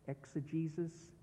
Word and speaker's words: Exegesis: Exegesis is spelled E-X-E-G-E-S-I-S. I tell you how it is spelled so you will understand that although it sounds like Jesus, as in Jesus of Nazareth, that's just a coincidence.